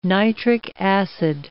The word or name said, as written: nitric acid